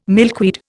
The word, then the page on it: milkweed: Indian